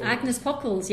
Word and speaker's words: Agnes Pockels: Agnes Pockels, yes.